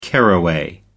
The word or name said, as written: caraway